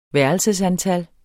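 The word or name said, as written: værelseantal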